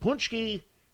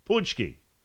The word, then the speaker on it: Pączki: And Pączki, I know you'll be shocked by this, but it's spelled, in no way does the spelling resemble the pronunciation of the name. It just doesn't. P-A-C-Z, hang on, I got it. P-A-C-Z-Y, I wrote it down like you.